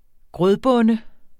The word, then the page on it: grødbonde: ˈgʁœð-